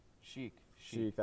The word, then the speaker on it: Chic: There's another one, Chic, I think. – Chic, that's how you say it.